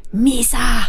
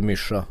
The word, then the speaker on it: Misa: Är det så man säger? – Misa! – Inte Mischa. Nej, okej.